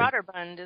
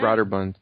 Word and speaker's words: Broderbund: It chronicles a lot of his, like, experience with, is it Borderlund? How do you say it? Broderbund, isn't it? – Broderbund, there we go.